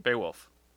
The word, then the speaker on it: Beowulf: Beowulf.